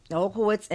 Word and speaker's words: Olkiewicz: Writers are Zach Galifianakis. – No, Olkiewicz. – Okay. – Olkiewicz, and it is based off of a novel, and I totally cut off the writer's name.